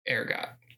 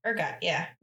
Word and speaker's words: ergot: LSD, or lysergic acid diethylamide, was synthesized by Swiss chemist Albert Hofmann in 1938 from lysergic acid derived by processing ergot. It's ergot. Ergot? – How do you spell it? – E-R-G-O-T. – Ergot, yeah.